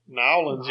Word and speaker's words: New Orleans: Yeah, Nolens, y'all. – That's how we say New Orleans. – How do you say New Orleans? – New Orleans.